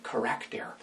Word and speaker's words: charakter: Character is actually a Greek word. They simply pronounce it charakter.